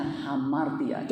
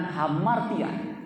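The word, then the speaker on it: Hamartia (ἁμαρτία): Hamartia, tulisannya Amartia. Ya, bacanya Hamartia. Coba katakan Hamartia.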